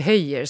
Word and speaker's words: Höller: Hans, hans efternamn stavas ju med två L, alltså Höller, men man uttalar det Höjer, som jag har förstått det i alla fall.